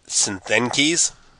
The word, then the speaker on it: Csthenkes: It's spelled C-S-T-H-E-N-K-E-S. Looks a little Greek. I'm going to go with Synthenkes.